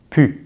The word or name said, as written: pu